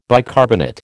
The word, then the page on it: bicarbonate: American English